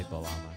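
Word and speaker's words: bağlama: It's spelled B-A-G-L-A-M-A, but there's a funky Turkish G that I don't know how to say, so you just say balama.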